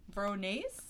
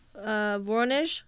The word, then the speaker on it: Voronezh: What's how did you pronounce that? Voronezh? – Voronezh? – Voronezh? Sorry, all our, like, 200 Russian.